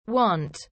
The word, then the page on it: want: Australian English